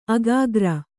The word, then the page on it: ಅಗ್ಗುರಿ: agguri